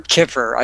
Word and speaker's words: kefir: You know, I think they just involve a grain and uh, Keeper or Kiffer. I think that's how Ray Peat pronounces Kiffer.